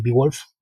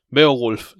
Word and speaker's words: Beowulf: Beowulf, le digo yo. – Ah, no, es que se supone que es en inglés antiguo, entonces la O la tienes que perder.